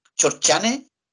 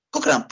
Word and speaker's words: Cochrane: Cuando era chico, yo pensé que el almirante se llama Chorchane y es Cochrane, pues, bueno. – bueno yo también decía Cochrane. Yo decía Crochane, Crochane, no, es Cochrane.